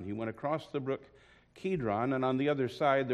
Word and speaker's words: Kidron: Kidron, we always pronounce that with a hard C, if you will.